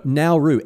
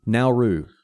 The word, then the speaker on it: Nauru: No, Nauru. N-A-U-R-U. Nauru. – Oh, Nauru. I thought it's an N-A-R-U. – I said it wrong.